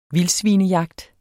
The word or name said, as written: vildthandel